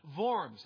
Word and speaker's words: Worms: In Germany, the city that they met in was actually pronounced Worms. It's a V sound in German.